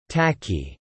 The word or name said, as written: tacky